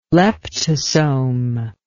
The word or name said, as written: leptosome